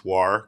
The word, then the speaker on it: WAR: Let me pronounce WAR correctly for you guys.